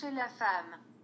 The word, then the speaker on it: Cherchez la femme: Cherchez la femme.